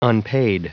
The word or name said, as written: unpaid